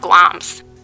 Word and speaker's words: Glamis: It's spelled Glamis, but it's pronounced Gloms.